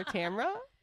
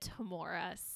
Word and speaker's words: Tamora: So her name is spelled T-A-M-O-R-A. – Tamora. No. – So, and I have heard like multiple people, including Allison, pronounce it as Tamora. And honestly, at first I just assumed that I was wrong in calling her Tamra. – Because I'm older and I have more lived experience. – That was like, I just had always called her that. And so when I was like, oh, I'm pronouncing her name wrong.